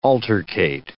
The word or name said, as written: altercate